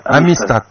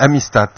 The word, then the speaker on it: Amistad: Donc c'est Amistad. On prononce pas le D à la fin. – Mais en fait, ça se prononce un peu comme un Z très léger, Amistad.